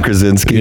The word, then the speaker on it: Krasinski: John Krasinski.